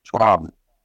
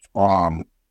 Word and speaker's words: C'waam: C'waam. – C'waam. Am I saying that right? – Yeah. It's like a T and C together. C'waam.